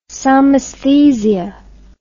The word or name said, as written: somesthesia